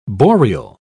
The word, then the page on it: boreal: British English